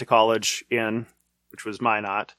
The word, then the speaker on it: Minot: Yes, it's M I N O T, but it's pronounced Minot.